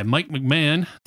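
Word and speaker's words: Mike McMahan: Anyway, Star Trek Lower Decks was created by Mike McMahon.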